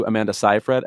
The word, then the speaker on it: Amanda Seyfried: I want to give a shout out to Amanda Seyfried, and I know I'm saying it right because her name rhymes with rye bread.